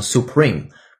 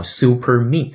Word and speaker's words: Supreme: 刚好在上期的新闻期节目当中，我们也讲过 Supreme 这个单词，表示至高无上的，比如最高法院叫 Supreme Court，这个词一定读成 Supreme，不是我们经常调侃的叫 Super Me 啊，那个是错的。